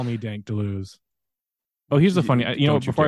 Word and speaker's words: Deleuze: The first time you were ever on this podcast, I looked it up exactly how to pronounce Deleuze because we say it in the English tongue, but it's more like Deleuze if you say it in French.